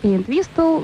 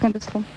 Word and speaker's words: Entwistle: Он у нас Энтвистл, Энтвистл, но вообще-то на самом деле он Энтвистл.